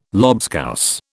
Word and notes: lobscouse: British English

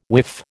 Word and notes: whiff: American English

